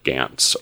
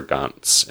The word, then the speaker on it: Gantz: which is the titular Gantz or Gantz in the Japanese pronunciation.